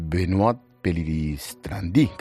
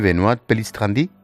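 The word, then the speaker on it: Benoît Pellistrandi: ¿Lo he dicho bien, Benoît Pélisse-Trandy? – Perfecto, vale. Perfectamente, y como pueden saber,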